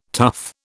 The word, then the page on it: tuff: British English